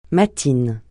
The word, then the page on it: matines: francais